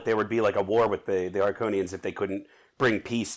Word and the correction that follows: Harkonnens: I gotta correct you. Just say Harkonnens.